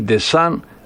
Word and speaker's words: Ντεσάν: Και ο νυν προπονητής ο Ντεσάν, παρότι γράφεται με MP αλλά προφέρεται με Νι Ντεσάν, έχει 103.